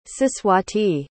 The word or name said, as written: siswati